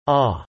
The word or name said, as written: awe